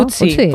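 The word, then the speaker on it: Ötzi: Otzi, creo que se llamaba, un nombre muy, muy noruego. – Utzi, ¿no? – Utzi, pues sí, Utzi. No sé, lo pronuncias tú mejor que yo. – Utzi, ah, vale.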